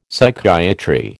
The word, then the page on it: Psychiatry: American English